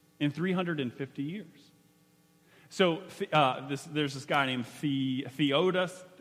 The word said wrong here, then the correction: Theodosius: I'll say it right, Theodosius the First.